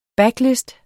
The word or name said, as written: backlist